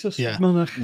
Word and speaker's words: Ystrad Mynach: Do Voice of Wales know about the recent attack by a charity on the sand track Ystrad Mynach? I can't pronounce that. – Ystrad Mynach, is it? – Yeah, it's Ystrad Mynach.